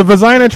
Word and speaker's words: Vezina: The Vezina Trophy, best goaltender. And yes, that's how it's pronounced now.